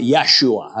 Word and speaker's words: Yeshua: They would pronounce it Yeshua. That's how they pronounce it in Hebrew.